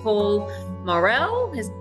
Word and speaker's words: Paul Morrell: And today I have with me Paul Morrell.